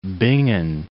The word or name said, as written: Bingen